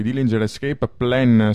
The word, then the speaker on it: Dillinger Escape Plan: ...1999, questa era Homicide, un tuffo nel passato, i Dillinger Escape Plan, se ho detto giusto.